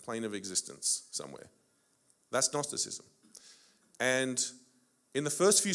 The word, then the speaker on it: Gnosticism: I don't know if any of you know what Gnosticism is. It's a strange word. It starts with a silent G, believe it or not. Gnosticism is an ancient philosophy,